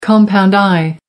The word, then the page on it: compound eye: American English